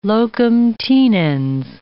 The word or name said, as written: locum tenens